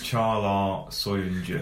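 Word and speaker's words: Çağlar Söyüncü: Charlar Soyuncu. – And you've checked that out, haven't you, with Turkish media? – Well, I've checked it out. Turkish people checked us out when we were saying it wrong.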